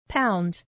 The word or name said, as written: pound